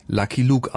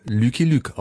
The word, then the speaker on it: Lucky Luke: Dabei lernt Goscinny jede Menge Comic-Autoren kennen, auch den Belgier Maurice, mit dem er später zusammen als Autor für den Comic Lucky Luke arbeitet, im französischen Original Lucky Luke ausgesprochen, der Cowboy, der den Colt schneller ziehen kann als sein eigener Schatten.